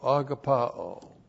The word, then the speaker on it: agapao: A-G-A-P-A long O, agapao.